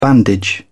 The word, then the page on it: bandage: British English